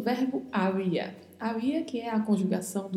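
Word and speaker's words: había: Haver em português, havia e haver, em espanhol, havia. A pronúncia tem que ser cuidadosa porque essa letra B é pronunciada com leve som de V.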